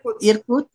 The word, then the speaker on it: Irkutsk: Irkutsk.